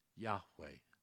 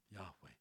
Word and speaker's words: Yahweh: Anytime you see Lord, all in capital letters, the Lord, it translates the holy name YHWH, that they pronounced Yahweh. Yahweh.